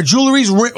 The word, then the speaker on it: jewelry: It's jewelry, not jewelry. – Jewelry. – No, jewelry. – No. Jewel. It's two syllables. Jewelry. – No, that's three syllables. – No, it's jewel. – Jewelry. Jewelry. – Yeah, but the word jewel is two syllables.